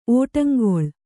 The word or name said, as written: ಒಡಂಗೊಳ್